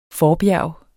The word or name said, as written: forbjerg